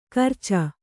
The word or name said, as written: ಗೋರೋಚ